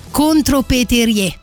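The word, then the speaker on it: contrepèterie: Che poi c'è una tradizione invece francese ehm, chiamata contropeterie. Eh, o contro Peteri, credo si dica, per le mie reminiscenze francesi la E finale senza una S non si legge.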